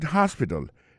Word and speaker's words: hospital: She likes to visit hospital. Lire mais à visiter l'hôpital. L'hôpital c'est comme ça on dit ça en anglais. Hospital. Alors accent tombe sur premier syllabe là, gain trois syllabes. Puis she likes to visit hospital. Hospital là écrit H-O-S-P-I-T-A-L.